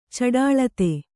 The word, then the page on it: ಚಟುಳಿತ: caṭuḷita